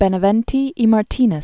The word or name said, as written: Benavente y Martinez